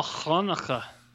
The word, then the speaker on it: Hanukkah: Oh, Hanukkah. – There you go. Like you're clearing your throat.